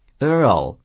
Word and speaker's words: Earl: そして、 L の音に行く前に、曖昧な、という、軽く喉から出すような、という音を挟むような言い方になりますので、 Earl のようになります。